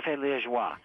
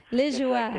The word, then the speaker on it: Liégeois: Café Liégeois. – Liégeois.